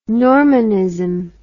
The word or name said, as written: Normanism